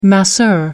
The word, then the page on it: masseur: American English